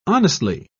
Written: honestly

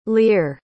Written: lear

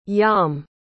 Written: yom

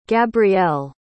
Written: gabrielle

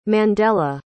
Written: Mandela

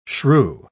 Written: shrew

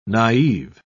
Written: naive

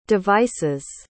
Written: devices

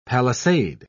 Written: palisade

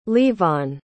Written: levon

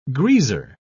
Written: greaser

